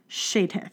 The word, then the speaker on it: Csejthe: It's spelled C-S-E-J-T-H-E, Shatith.